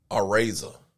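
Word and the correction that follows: Arraez: It's Arez. You gonna do that? Arez.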